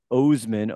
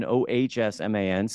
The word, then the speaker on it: Oshman: It's really pronounced. It should be pronounced Oshman, Oshman, Scott.